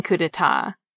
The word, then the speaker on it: coup d'etat: The same goes for the silent P and silent T in coup d'etat, and the silent D and silent X in Grand Prix.